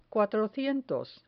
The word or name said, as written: cuatrocientos